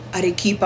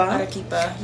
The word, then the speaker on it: Arequipa: Arequipa. – Arequipa, yeah.